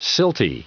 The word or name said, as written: silty